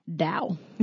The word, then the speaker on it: Taoist: It's spelled Taoist, but it's pronounced Taoist. So FYI, if you uh, think like me, Dow. Dow.